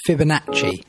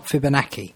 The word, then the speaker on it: Fibonacci: It's Fibonacci, not Fibonacci. So there we go.